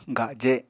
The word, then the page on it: gaje: apatani